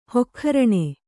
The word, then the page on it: ಹೊಕ್ಖರಣೆ: Kannada